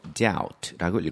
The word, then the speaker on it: doubt: 읽을 때 b는 묵음이라 안 읽고 doubt라고 읽어야 되죠.